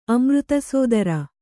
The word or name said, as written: ಅಮೃತಸೋದರ